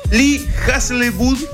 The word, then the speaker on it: Hazlewood: Sun Velvet Morning de Lee Hazlewood.